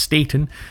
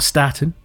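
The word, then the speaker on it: Staton: Yes, I'm reliably informed it is actually pronounced Staten, not Staten.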